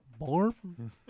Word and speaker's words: Böhm: B-O-H-M, but there's an umlaut over the O. How do you say it? – Toilet bowl cake. – Too mean. – B-O-H-M? – B-O-H-M, boom. – Like but it's Bjork. So Boom. – Because she has the umlaut, so it makes like a or sound type sound, right?